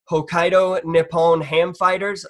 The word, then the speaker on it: Hokkaido Nippon Ham Fighters: Hokkaido Nippon Ham Fighters.